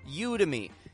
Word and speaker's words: Udemy: This is a website called Udemy.